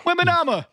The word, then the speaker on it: Wembanyama: They're not pronouncing Wembanyama correctly. – No. – Wembanyama. – Wembanyama, yeah.